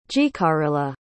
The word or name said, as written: jicarilla